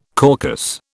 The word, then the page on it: caucus: British English